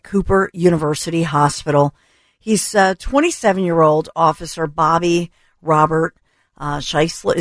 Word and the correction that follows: Schisler: Is it Schisler or Scheisler, Anthony? I apologize if No, it's okay. It's Schisler. – It is Schisler.